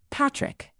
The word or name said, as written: patrick